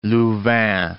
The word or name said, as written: Louvain